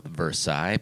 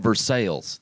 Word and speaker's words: Versailles: There are some cities in Indiana named after European cities, but they're pronounced horribly American or Indiana-in. – Like French Lick? – There's a Versailles, you know, Versailles, but in Indiana, I'm from Versailles.